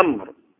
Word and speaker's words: عمرو: لكن لما يجي بقى حتى ما طيب جاء عمرو او جاء عمرو فنعمل واو، جاء عمر بدون واو، معناه النطق واحد، هو جاء عمر جاء عمر النطق واحد، لكن الكتابة اختلفت بالواو.